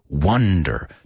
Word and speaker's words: wonder: Wonder. To ask oneself, right? I was just wondering. Wonder. Sounds like the word one. And the other's wan.